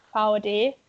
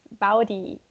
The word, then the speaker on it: Vaude: How would you pronounce it in German? – The German version would be Vaude. But I know that the English version is Baudi, so, yeah.